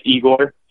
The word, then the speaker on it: Egor: And my name is Egor, so Egor Afanasyev. – Oh, so you don't pronounce as Yegor. We have a couple Russian players up here with the same first name, but they say Yegor, not Igor.